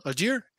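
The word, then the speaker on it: Allgeier: Algier, ¿lo dije bien, chato? Algier. Muy bien. Muy bien.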